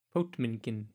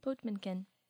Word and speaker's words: Potemkin: It's Potemkin. – Potemkin.